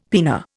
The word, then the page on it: pinna: Indian